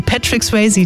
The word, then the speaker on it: Patrick Swayze: Jetzt kann ich ihn schon nicht mehr aussprechen. Patsy Sweatsy, Patrick Swayze, Don't Sie Ding Sie.